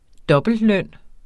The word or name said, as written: dobbeltløn